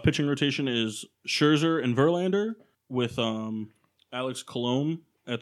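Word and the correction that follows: Colomé: the closer, is it Colom? – Colome. – It is Colome. It's a little accent on the E.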